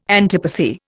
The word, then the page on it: antipathy: Indian